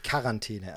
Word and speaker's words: Quarantäne: Also ich sage dazu tatsächlich Quarantäne. Also ich spreche es mit einem K an der Stelle, aber es wird mit einem Q geschrieben und ich kenne tatsächlich auch Menschen, die Aussprache Quarantäne verwenden.